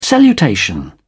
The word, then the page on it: salutation: British English